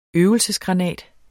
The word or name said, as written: øvelsesgranat